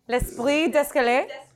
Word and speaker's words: l'esprit d'escalier: Say it again. – Am I saying it right? – L'esprit d'escalier. – D'escalier.